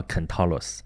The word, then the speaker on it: Centaurus: 你那个如果是按拉丁文来发音的话，我这不一定准啊，但是勉强接近吧，叫 Centaurus 啊，肯陶洛斯。